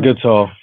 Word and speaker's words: Goodsall: We got to hang out with John Goodall from, how do you pronounce it? – John Goodsall from Brand X.